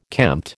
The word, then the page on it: kempt: American English